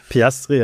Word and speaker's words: Piastri: Piastri, also.